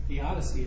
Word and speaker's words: theodicy: So that is this theodicy. How are we going to pronounce that? How do we pronounce it? – Theodicy is what I thought. – All right. – Like theodicy. Yeah.